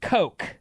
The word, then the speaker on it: Koch: We had a teacher in high school named Mr. K-O-C-H. He pronounced it Coke.